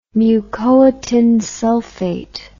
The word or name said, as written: mucoitinsulfate